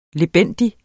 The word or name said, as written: lebendig